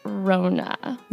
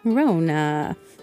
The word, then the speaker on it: Rhona: And the puppet's name is Rona. – Rona. – But it's spelled R-H-O-N-A.